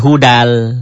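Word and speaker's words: hudal (هُدًى لِلْمُتَّقِينَ): Ditulisnya, dibacanya menjadi hudal. Tanwinnya ketemu lam, masuk L.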